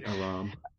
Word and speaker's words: Aram: But I think this is after, that comes after Amaran comes to dinner. – Aram? – Aram. Oh, Aram? – Yeah, A-R-A-M, Aram.